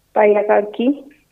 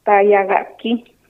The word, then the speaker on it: Tayagaki: Radio, ¿habrá la palabra radio? ¿Será que radio habrá? – Tayagaki. – Ah. ¿Cómo se dice? Reiterame eso. – Tayagaki. – Tayagaki. ¿Así? – Vos.